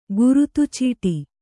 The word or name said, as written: ಖಿರ್ದಿಚಿಟ್ಟಿ